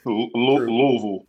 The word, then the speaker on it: Louisville: But I noticed you said Louisville, and that's not how you pronounce it if you're from Kentucky. So I thought for there's way too many syllables.